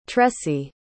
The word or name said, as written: tressie